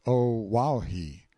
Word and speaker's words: Oahu: I named him after this island, Oahu, pronounced O-Wahi.